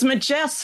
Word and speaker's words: Majess: And it's bracketed here, the Majess Files, A-M-A-J-E-S-S. Is that the pronunciation? – It's Majess Files.